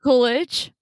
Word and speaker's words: Coolidge: Jennifer Coolidge. Is that how you pronounce her name? – Yeah, Jennifer Coolidge.